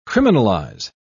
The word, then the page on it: criminalise: British English